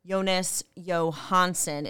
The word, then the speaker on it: Johansson: But, yeah, Chris, these other two goalies, Jonas Johansson is the correct pronunciation.